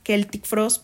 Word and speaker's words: Celtic Frost: Pero yo creo que lo que venimos a ver los fans en temas de black metal, pues bueno, obviamente está Celtic Frost, porque pues bueno, nos referimos así porque así se pronuncia.